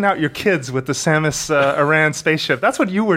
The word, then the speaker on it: Samus Aran: it's pronounced Samus Aran.